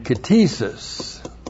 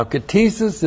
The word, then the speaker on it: ktisis: he is a kainos katesis. No way. You know what? You want to be one? Yeah, you want to be one of those. Kainos new and katesis. K-T-I-S-I-S. Now, katesis is the noun that comes from the verb katizo. Looks like that. K-T-I-Z, long O.